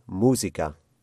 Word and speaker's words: música: And if you pronounce that word as we would say it in Spanish, then what would it be? – It would be música.